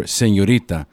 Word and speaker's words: Señorita: Actually, it's pronounced Sober Señorita. I'll bring out my Latino flavor.